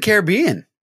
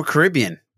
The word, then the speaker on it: Caribbean: Pirates of the Caribbean or Caribbean, depending on how you say it.